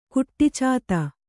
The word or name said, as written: ಕುಟ್ಟಿಚಾತ